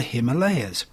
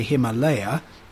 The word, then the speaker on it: Himalaya: Or is it, in the sense that, is that how it's pronounced? Well, no, it's not. You can say Himalaya. Westerners tend to like putting S's on the end of things. But I understand it is more correctly pronounced Himalaya.